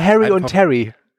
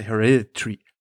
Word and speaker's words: Hereditary: Der hat zuvor gedreht Midsommar und den Film, den ich immer falsch ausspreche, ich nenne ihn jetzt einfach mal Hereditary. Ich sag immer Harry und Terry. – Harry und Terry gilt, wenn du sagst. Ansonsten heißt er Hereditary.